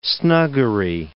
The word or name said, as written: snuggery